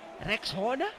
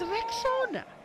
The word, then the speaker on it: Rexona: A friend even called it Rexona, but guys, it's Rexona, like Rex and Ona, Rexona.